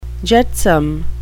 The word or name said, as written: jetsam